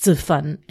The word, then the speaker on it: Zefeng: So my first name is Zefeng, but it's pronounced Zefun, and the Z part of it is from the poem.